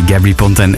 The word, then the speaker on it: Gabry Ponte: DNA, Gabri Ponten, Jovanotti, zo is het ook juist uitgesproken.